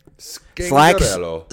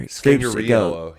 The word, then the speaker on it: Scangarello: I don't know how to pronounce this guy's last name. – Scangarello. – Sacks gave something else. – What? Sacks gave? – Slacks gave one pronunciation. – Scangarello. – And then Mark gets up there. – Scangariello. – Scangariello. Yeah. – He made it extra Italian.